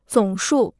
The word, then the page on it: 总数: zǒng shù